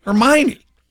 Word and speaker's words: Hermione: I had never seen or heard of that name, Hermione, and it's spelled like Hermoine. So we were reading to our kids, like, we didn't say it right. And then the movie came out, I think the whole theater went, oh, Hermione.